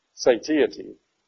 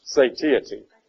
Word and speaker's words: satiety: I had to stop and think, how do you pronounce that? Huh? Satiety. Yeah. It comes from the word satious, you know, meaning filled up. Yeah. But I had to look it up in the dictionary, and it's satiety.